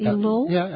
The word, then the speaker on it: Elul: Elul.